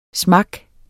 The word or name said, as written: smak